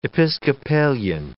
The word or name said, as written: Episcopalian